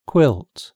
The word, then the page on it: quilt: British English